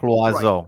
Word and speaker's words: Loiseau: How is his name pronounced? – I never interviewed him, but I played the interview. Right. Mark Loiseau. Right. Mark right. Loiseau. I think it means that, bird in French.